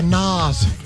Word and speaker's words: Nas: Yeah, Nas. – Oh, you know how to pronounce it now.